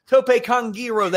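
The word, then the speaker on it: tope con hilo: Tope Kongiro, they call it now. We've been saying it wrong for decades.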